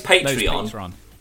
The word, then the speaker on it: Patreon: I can't say... Why can I never say that properly? The website is Patreon.